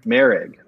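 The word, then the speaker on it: Moehrig: I mean, if I have a 1A and a – if I have Morig – and how do you say Morig? – Merig.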